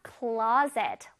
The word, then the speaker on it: closet: closet. One more time. closet. 옷장, 벽장입니다. 우리 왜 혹시 침실에 이렇게 옷장 있는 친구들 있나요? 선생님은 그렇거든요. 자, closet는요. 발음 CLO에서 강세가 들어가요. closet. closet. 오케이.